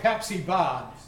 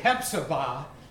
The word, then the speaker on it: Hephzibah: And then it's like Pepsi Ba, but Hephzibah, that's how you pronounce it.